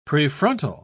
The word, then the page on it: prefrontal: British English